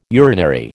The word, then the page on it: urinary: American English